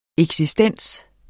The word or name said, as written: eksistens